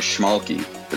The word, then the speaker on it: Schmalke: My last name is Schmalke. It's spelled S C H M A L K E dot com.